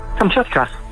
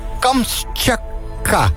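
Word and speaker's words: Kamtsjatka: Hoe zeg je het nou? – Kamtschatka. – Kamtschatka. – Ja. – Oh ja, maar dat simpel is het. – Er staat er ook nog een T bij. Kamtschatka.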